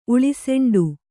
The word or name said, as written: ಉಳಿಸೆಂಡು